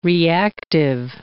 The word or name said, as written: reactive